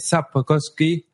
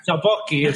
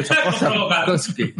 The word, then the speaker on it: Sapkowski: Apoyo de el señor André Sapkowski. – No sé si lo he dicho bien. Ah, no, no has escupido, no lo has dicho bien. Sapkowski, Sapkowski.